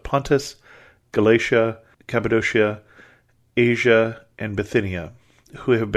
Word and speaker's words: Cappadocia: I have a little trouble when I'm going to pronounce Cappadocia, because we tend to pronounce it that way in the church. If you're in modern Turkey, it's Cappadocia now, but it's still a region that you can go to with its magnificent spires and troglodytes and things like that.